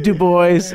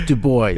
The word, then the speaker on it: Du Bois: He pronounced it Du Bois. It's Du Bois.